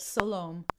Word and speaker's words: Sol-Aum: Sol-Aum. Mm. And did I say that right, by the way? – Perfect. Sol-Aum. Yes. – Sol-Aum. I love it. It sounds like shalom.